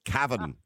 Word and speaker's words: Cavan: If you're going to come on to Irish radio, you've got to pronounce this properly. You've got to get rid of that Australian twang just for a moment. Okay? So it's Cavan. Or as they say in Cavan, they don't actually say Cavan and Cavan.